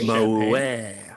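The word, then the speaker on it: Moët: Moet.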